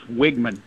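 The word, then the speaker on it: Weigman: Okay, the E throws things off because it's spelled W-E-I. – Yeah. – It is Wigman, W-I-G.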